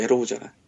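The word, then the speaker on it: Arrow: 근데 저 TV에서 그냥 에로우잖아. 음, 에로가 아닙니다. – 에로상이 꼽힌다의 에로가 아니야 그래. – 아 발음을 잘해 에로.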